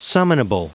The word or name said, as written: summonable